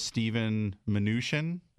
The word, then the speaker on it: Steven Mnuchin: So the Senate Finance Committee was having a hearing for this Steven Mnuchin. Is that how you say it? – Yes.